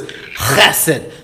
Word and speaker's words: chesed: The Hebrew word is chesed. It's got a good guttural. You spit when you say it, or you're not saying it right.